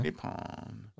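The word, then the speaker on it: Nippon (日本): I think it's how the Japanese pronounce Japan, Nippon. N-I-P-P-O-N, Nippon.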